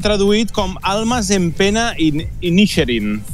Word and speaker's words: Inisherin: Comencem per The Banshees of Inisherin. No sé si ho he pronunciat bé. – Sí, més o menys, suposo. Aquí l'hem traduït. A mi em costa molt, també. Inisherin.